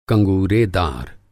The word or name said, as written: कँगूरेदार